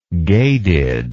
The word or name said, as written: gadid